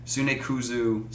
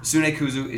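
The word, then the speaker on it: Tsunekazu: So one of the guys, Sunekuzu, yeah, I said it right the first time. Should I make Wes read these names? Ishihara. Sunekuzu, Ishihara, and some other former Ape staff after Ape dismantled went on to form the game development company Creatures.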